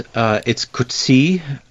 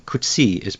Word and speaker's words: Coetzee: Yes, it's Coetzee. I think if you wanted to pronounce the name with its full Afrikaans resonance, you would have to say something like Coetzee. But John Coetzee comes from the Cape, where there's a lot of bilingualism, and I think there's a slightly English emphasis in the pronunciation of the name, so Coetzee is reliable.